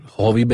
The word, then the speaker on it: Hovey: H-O-V-E-Y. – Okay. – Hovi Benjamin. Benjamin spells as Benjamin spells. – Cool. Hovi Benjamin, please check out Send to Bob.